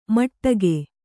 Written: ಮಟಕು

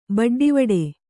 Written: ಬಡ್ಡಿವಡೆ

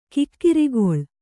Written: ಕುಕ್ಕರಗಾಲು

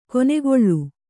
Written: ಕೆನೆಗೊಳ್

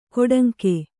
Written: ಕೊಡಂಗೆ

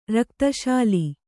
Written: ರಕ್ತಶೂಲೆ

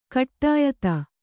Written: ಕುಡಿಯಿತಿ

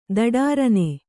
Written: ಧಡಾರನೆ

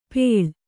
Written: ಪೇಳ್

